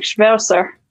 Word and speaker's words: Schwerzer: So it's like Schwerzer.